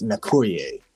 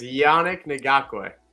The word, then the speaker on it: Yannick Ngakoue: Nakuye, defensive end for the Indianapolis Colts. – I think it's Yannick Ngakoue. – Ngakoue.